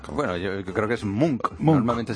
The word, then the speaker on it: Munch: Se dice Munch. – Munch. – Como si fuera una K.